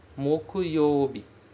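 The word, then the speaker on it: mokuyobi (木曜日): Em seguida, temos a quinta-feira, mokuyobi. Vou colocar aqui, quinta. Quinta. Então, moku é a pronúncia e o yobi vai ficar lá intacto. Ele não vai mudar, ok?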